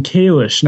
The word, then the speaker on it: Kalish: See, my name is actually pronounced Jordan Kalish, not Jordan Kalish.